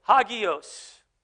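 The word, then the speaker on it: hagios (ἅγιος): Holy, hagios. That's how you say it. That's how I'm going to say it.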